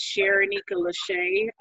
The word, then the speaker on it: Sharenika Lashay: Okay, my name is Sharenika Lashay.